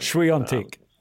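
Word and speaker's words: Świątek: Swastiger. I call her Swastiger, but I can't pronounce her second name. – Swiatek. – Swiatek, yes.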